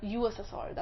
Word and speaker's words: USSR: Back to SSSR. USSR, that's how you say it.